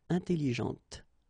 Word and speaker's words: intelligente: When you put this well, actually, you will pronounce the final T, okay, but you don't pronounce this here, intelligente.